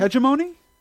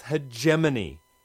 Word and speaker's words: hegemony: Hegemony? – Oh, jeez. No wonder it's frayed around the edges. Words are not meant to stand up to their secondary pronunciations. It's hegemony. Hegemony.